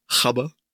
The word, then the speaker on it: Gabber: Die Doku heißt Die neuen Gabber Hardcore Never Dies oder wie es die Holländer so schön aussprechen, Chabber. – Ja, Chabber, du.